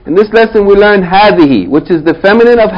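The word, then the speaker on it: hadhihi (هذه): Hadhihi. Hadhihi sayyaratun. Okay, it's the feminine of hadha. It is pronounced hadhihi with a long vowel. Ha fatta alif. But the alif is omitted in writing.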